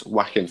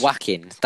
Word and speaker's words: Joaquin: It's Joaquin Phoenix. – Joaquin. That's how you say his first name.